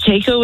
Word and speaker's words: Taco: It's Taco with a T, yeah.